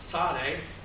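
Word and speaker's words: Tsade: Sade is kind of pronounced as a TS together. If you think of the word cats, for example, it's a TS together. That's probably how you pronounce the letter Sade, with that TS sound together.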